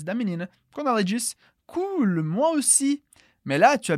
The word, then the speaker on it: cool: «Cool». «Cool». E eu acho que você percebeu que a pronúncia é à la française, um pouco diferente do inglês. Portanto, a gente diz cool.